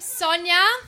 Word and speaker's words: Sonia: My name's Sonia. Hi, that's Tonya with an S. Like a snake trying to pronounce Tonya. Sonia.